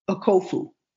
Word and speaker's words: Okofu: I-M-A-H-K-U-S, that's Amicus, N-J-I-N-G-A-N-Jinga, Okofu, mm-hmm. O-K-O-F-O, One Africa. – O-K-O-F-U. – O-O-F-O-K-U.